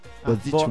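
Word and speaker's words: Boğaziçi: Sì, Boazic. Boazici Università, okay. Che si scrive Boazici come lo stretto sul Bosforo.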